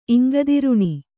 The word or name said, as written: ಇಂಗದಿರುಣಿ